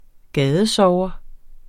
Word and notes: gadesover: -ˌsɒwʌ

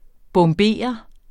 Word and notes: bombere: [ bɔmˈbeˀʌ ]; [ bʌm- ]

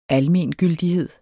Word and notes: almengyldighed: ˈalˌmeˀnˌgyldiˌheðˀ; ˈalˌmeːn-

